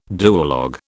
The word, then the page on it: duologue: British English